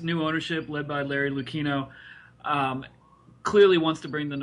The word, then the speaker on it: Lucchino: You know, the new ownership headed by Larry Lucchino, and is that how you say it? – Lucchino. – You know, it's tricky because he has both the two C's and the H in there.